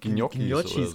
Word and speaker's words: Gnocchi: Nee, ich kannte mal äh, jemanden, der hat Gnocchis, so.